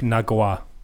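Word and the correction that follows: Ngakoue: The correct pronunciation is Yannick Njakwe. – What? How is there a J? – Yeah, like none of them were close. – I think can't. – Wait, can you just describe? Maybe I fucking slept in language power class, but how does an NG pronounce a J?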